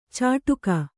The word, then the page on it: ಚಾಟುಕ: cāṭuka